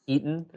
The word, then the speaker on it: Hiten: I know you say my name the Indian way. Most people say it Hiten.